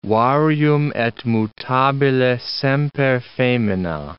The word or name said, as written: varium et mutabile semper femina